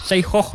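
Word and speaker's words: Syekhah: Buku biografi karya dari Syekhoh ya bacanya.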